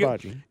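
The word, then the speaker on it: Agbaji: Well, if I watch, you know, Abaji, I think that's how we pronounce Ochai's name. Abaji. – Ochai, like, Abaji.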